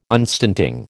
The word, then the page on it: unstinting: American English